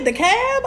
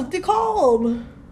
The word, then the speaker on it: DeKalb: That's how you say it, DeKalb? I thought it was DeKalb.